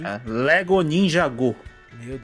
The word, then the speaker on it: Ninjago: Ninjago. É Ninjago? – É. – Mentira. Go. Eu falei errado a minha vida inteira. É, é Ninjago. Pra é Ninjago.